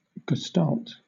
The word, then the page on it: gestalt: British English